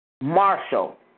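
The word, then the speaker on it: Marshall (משל): Marshall, MASHAL.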